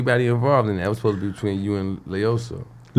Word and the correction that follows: Loyiso: You said his name wrong. – Loiso. – Yeah, it's very phonetic. It's Loiso.